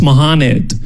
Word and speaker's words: Mohamed: So he asked me, oh, how do you pronounce this? I was like, oh, it's Mohamed. And he was like, huh. You know, actually, here in the States, it's pronounced Mohamed.